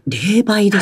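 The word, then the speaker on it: 零売: そうなんですよね。はい、もう一つね、問題になっているのが、はいはい、あまり聞かれたことないかもしれないですが、霊媒ってあるんです。 – はい、霊媒ですか。 – はい、漢字でですね、ゼロっていう漢字ありますよね。 – はい、ゼロありますね。 – と、売ると書いて、はい、これ、霊媒っていうふうに読むんです。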